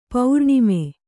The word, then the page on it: ಪೋರಾಣ್ಮ: Kannada